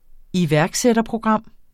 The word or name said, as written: iværksætterprogram